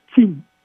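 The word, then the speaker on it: Chin (秦): Tần cũng là cái chữ phát âm là Chin, và người Tây phương và cả thế giới gọi là China.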